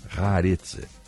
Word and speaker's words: Haaretz: O, ontem o Hamas divulgou um vídeo que mostra é, algum, alguns reféns Segundo o jornal Times of Israel e Haaretz, Haaretz, Haaretz, essa é a pronúncia correta, Haaretz, trata-se de Mia Shem, de 21 anos, que tem cidadania francesa e israelense.